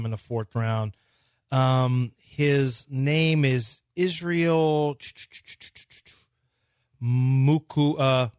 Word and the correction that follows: Mukuamu: Del, how do you say it, Del? You're Nigerian. How do you say this? – Yeah, Del. – Mukuamu is how it is.